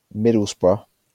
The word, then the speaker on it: Middlesbrough: No, well, that's how it's pronounced. Middlesbrough.